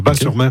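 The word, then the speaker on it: Batz-sur-Mer: Et surtout, la crêperie s'appelle le Dervin à Bas-sur-Mer, qui font les meilleures crêpes de Bat. Moi, on prononce Bas, le T ne se prononce pas. Bas-sur-Mer.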